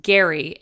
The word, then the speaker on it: Gerry: First off, during this episode, I referenced Gary, aka the Golden Bachelor, multiple times as Jerry. His name is in fact pronounced Gary, despite being spelled G-E-R-R-Y. And I respect that.